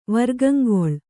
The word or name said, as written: ವರ್ಗಂಗೊಳ್